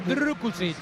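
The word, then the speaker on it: Drkušić: Hay que pronunciarlo Drukušić.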